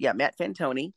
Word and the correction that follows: Fantone: Fantone. – The new host. – Fantone. – Sorry. Yes. So the new show is Mixed Mornings with Matt Fantone, and he is weekday mornings from 6 to 10 Eastern.